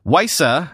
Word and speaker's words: WiSA: WiSA, I think I'm pronouncing that right, it's W-I-S-A,